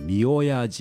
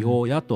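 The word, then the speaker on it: 御祖: 御親神社と申します。お礼などの御という字に祖先の祖と書いて御親と発音いたしますが、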